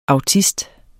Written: autist

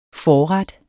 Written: forret

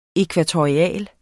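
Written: ækvatorialt

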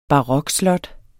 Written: barokslot

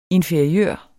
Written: inferiør

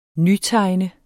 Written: nytegne

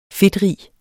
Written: fedtrig

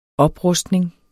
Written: oprustning

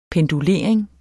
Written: pendulering

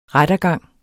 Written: rettergang

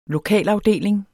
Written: LOKALAFDELING

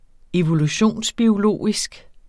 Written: evolutionsbiologisk

